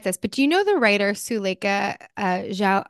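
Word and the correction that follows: Jaouad: I think her last name is pronounced Zhaoud. Do you know Suleika Zhaoud?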